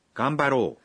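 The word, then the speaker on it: غامبارو (がんばろう): أي دعنا نجتهد هذه الكلمة مكونة من الحروف الخمسة غ م ب ر أ وتنطق غامبارو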